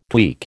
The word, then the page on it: tweak: American English